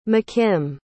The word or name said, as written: mckim